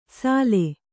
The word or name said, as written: ಸಲೆ